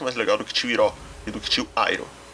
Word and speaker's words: Iroh: Ah, eu estou escutando ainda, mas a pronúncia de Hiro em inglês é Iro mesmo.